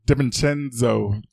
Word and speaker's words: DiVincenzo: I think it's DiVincenzo. – Are you sure the DI is not silent?